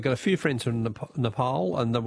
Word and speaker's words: Nepal: We're all saying Nepal until someone, look, many people who are from that beautiful country says Nepal is the way we pronounce it. So that's the only reason I think across the ABC, we all went from saying Nepal to Nepal.